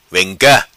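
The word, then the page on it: Vem Ca: patua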